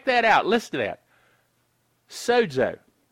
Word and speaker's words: sozo: S-O-Z-O is how it's transliterated into English, but it's pronounced with a little D sound, sozo.